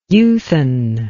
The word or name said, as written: youthen